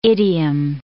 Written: idiom

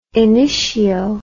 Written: initio

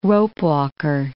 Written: ropewalker

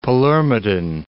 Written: Palermitan

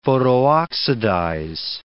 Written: photooxidize

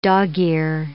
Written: dog-ear

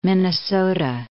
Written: Minnesota

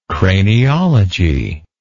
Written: craniology

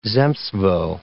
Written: zemstvo

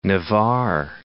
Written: Navarre

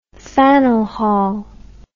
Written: Faneuil Hall

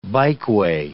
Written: bikeway

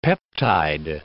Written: peptide

